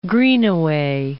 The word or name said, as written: Greenaway